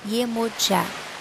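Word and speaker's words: Yemọja: Na Nigéria, Iemonjá pronuncia-se com o som de já, Iemojá, na última sílaba.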